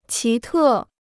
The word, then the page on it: 奇特: qí tè